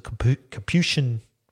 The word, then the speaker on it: Capuchin: 1577 to 1622 was a Capuchin.